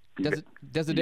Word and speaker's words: Desiderio: Carter Kena, he is a local architect with ties to, I'm going to butcher this, Desiderio Kena. Did I get it close? Desiderio. – You got it right.